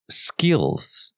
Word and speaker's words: skills: 啊、呃，就是 skills 这个字哈，一般我们在发音的时候，我们很难去发那个 l。各位听到我们那个 l，它是舌头在最后 l 有往上顶，顶到上顎。